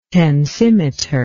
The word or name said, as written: tensimeter